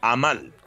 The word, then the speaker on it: Amal: se lee como se escribe y como palabra ah. aguda, amal.